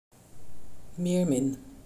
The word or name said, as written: meermin